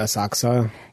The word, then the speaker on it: Asakusa: Do you pronounce it Asakusa or Asakusa? That's a good question, I think. – Asakusa.